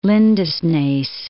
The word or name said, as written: Lindesnes